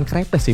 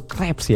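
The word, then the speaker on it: crêpes: Bukan krepes sih bacanya yang benar ya, itu krebs ya.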